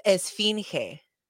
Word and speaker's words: Esfinge: You got it. It's Esfinge. – Esfinge. I was going to say Esfinge. And I'm like, that's not right. That's not right. That's you got two out of the three syllables.